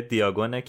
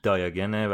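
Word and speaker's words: Diagon: فصل پنجم عنوانش کوچه دیاگونه که حالا تلفظ ترش دایاگنه ولی خب حالا ما هم دیاگون میگیم که راحت تر باشه یا من دیاگون میگم که تر باشه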